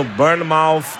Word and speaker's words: Bournemouth: Justin Kluivert joga no Bournemouth da Premier League.